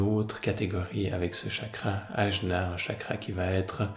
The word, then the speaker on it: Ajna: Sixième chakra appelé Ajna, prononciation plus, plus correcte serait Agnya qui est quelque part une autre, euh, qui appartient quelque part à une autre euh, catégorie.